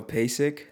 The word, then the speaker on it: Peisik: And so my real name is spelled P-E-I-S-I-K. Mm-hmm. So Pasic.